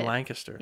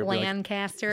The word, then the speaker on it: Lancaster: And you said it right. I didn't mean to cut you off, but you said Lancaster. – Yes. – Okay, because people normally from out of the area. – Lancaster.